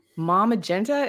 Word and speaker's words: Maw Magenta: So I have Mike and Geneva from Maw Magenta. Is that how you say it? – That's how you say it.